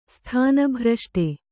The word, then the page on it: ಸ್ಥಾನಭ್ರಷ್ಟೆ: sthāna bhraṣṭe